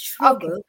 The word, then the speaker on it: Schrogl: So just to confirm, Natalie Schrogl, am I pronouncing your name right? – Not quite, it's Schrogl.